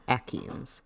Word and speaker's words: achene: Akeens. – Akeen. – So how do you spell it? – Nobody's ever told me how to pronounce it. I've just always heard Akeen. A-C-H-E-N-E.